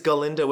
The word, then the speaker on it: Galinda: Yeah, she says it's Galinda with a gall, which technically it is.